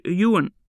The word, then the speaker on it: Ewan: Bonjour, je m'appelle Ewan. Ça s'écrit E-W-A-N.